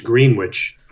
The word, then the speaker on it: Greenwich: It's spelled exactly like Greenwich, Connecticut, but it's Greenwich, New York, pronounced Greenwich. It's north of Troy.